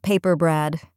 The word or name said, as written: paper brad